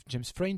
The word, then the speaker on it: James Frain: Euh, après, j'ai vu qu'il y avait James Fran, que, James Frain, pardon, que j'aime beaucoup, qui intégrait la distribution.